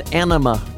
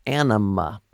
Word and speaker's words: Anyma: By the way, it's pronounced Anima Remix. Anima, by the way.